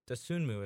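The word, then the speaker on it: Dosunmu: The one player to watch that we'll see if he's day-to-day at this point, Dasunmu, if I'm pronouncing it correctly. – You are.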